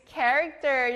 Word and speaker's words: character: Character. 여기 크 소리 나요.